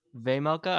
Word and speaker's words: Vejmelka: Wait, how the fuck do I pronounce that again? Vejmelka. – Vemelka, I think. Vemelka. All right, Vemelka.